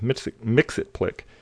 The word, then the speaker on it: Mxyzptlk: It's Mxyzptlk, pronounced Mxyzptlk.